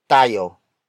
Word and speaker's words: tayo: The next word is tayo. Tayo. Tayo. Tayo is spelled as T-A-Y-O.